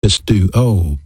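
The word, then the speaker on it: Pisteuo (πιστεύω): You know, faith is a noun, and in the Greek language it's pronounced pistis, P-I-S-T-I-S. It has a verb counterpart, and that's the word believe. Pisteuo, P-I-S-T-E-U-O.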